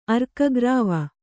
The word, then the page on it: ಅರ್ಕಗ್ರಾವ: arkagrāva